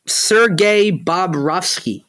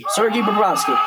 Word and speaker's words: Sergei Bobrovsky: Sergei Bobrovsky. – Said that all wrong. You butchered his Sergei. – Name. Sergei Bobrovsky.